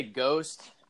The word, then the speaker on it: GOST: One of the openers is, I just say Ghost, but it's spelled G-O-S-T, and it's called Slasher Wave.